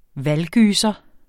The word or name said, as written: valggyser